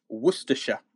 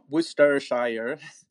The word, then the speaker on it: Worcestershire: It's Worcestershire, not Worcestershire, Worcestershire.